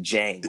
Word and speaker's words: Jang: I just think it's like Jang, like Django.